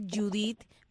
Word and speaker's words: Judith: Tengo también mi página de Facebook, que me puedes buscar, Judith. Mi nombre se escribe J-U-D-I-T-H.